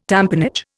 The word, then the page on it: tamponage: Indian